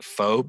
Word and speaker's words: pho: It looks like pho, but it's pronounced pho.